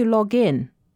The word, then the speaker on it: login: 此外，名词 login, L-O-G-I-N 的意思是登录名，它和 log in 的发音很相似。名词 login 的重音在第一个音节上，比如 What's your login? 而在用动词短语 login 的时候，通常重读 in，比如 I'm going to log in。